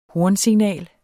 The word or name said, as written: hornsignal